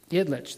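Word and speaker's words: Ilitch: His name was Mike Itilich. Or did I say that right? Itlich, there we go.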